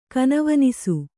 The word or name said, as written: ಕನವನಿಸು